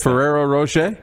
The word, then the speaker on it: Ferrero Rocher: But I don't know the name of how to pronounce the Ferrero Rocher.